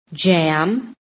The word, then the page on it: JAM: American English